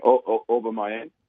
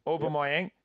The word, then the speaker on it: Aubameyang: And he went, Aubameyang. How do you say that? Pierre-Emerick Aubameyang. – Aubameyang, who's also $5.